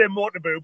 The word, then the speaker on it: Motobu: Okay, so by the power invested in me in Karate Unity, Ian Abernathy now is Choki Mutubo. Okay, Choki Motobu. I always mess up that name. How do you say it properly? Motobu? – It's Cumbrian accent. I would say Motobu, but either's fine.